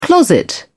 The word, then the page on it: closet: British English